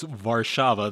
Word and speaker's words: Warszawa: Oh, yeah, well, it's Warsaw, and then it's Warszawa there, W-A-R-S-Z-A-W-A. I'll give you that one, Warszawa.